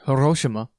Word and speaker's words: Hiroshima: Hiroshima.